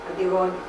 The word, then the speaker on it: Antigone: Antigone.